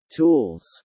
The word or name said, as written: tools